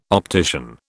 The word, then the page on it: optician: British English